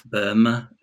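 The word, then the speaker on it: Böhme: And what Michael has very helpfully done is he's shown how influential Jakob Bohme is how I'd say his name. – Bohme.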